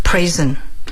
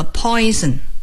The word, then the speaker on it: prison: Prison. – 发音不要和 poison, 毒品毒药混在一起。 Poison 是毒品， prison 是监狱。